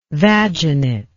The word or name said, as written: vaginate